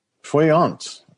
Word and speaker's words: Goeienaand: Goeienaand. I think I said it right. – Ja, yeah, kom al goed reg met die Afrikaans.